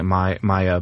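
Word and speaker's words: Maja: I think it's Maya. It's M-A-J-A, so I'm pronouncing it Maya,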